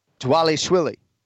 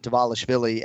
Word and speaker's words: Dvalishvili: Dvalishvili. So it's Merab Dvalishvili. It's not Dvalishvili, as I think we pronounced it, Ken Flo, at his last fight in September.